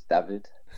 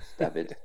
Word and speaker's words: David: It's David. David. – And this is because of a Czech connection. So this is where the pronunciation comes from.